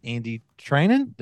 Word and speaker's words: Andy Treinen: One is the president and CEO, and that is Andy Trinan.